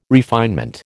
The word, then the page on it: refinement: American English